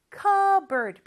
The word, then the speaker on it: cupboard: 근데 발음은 cupboard, P 발음 안 해요.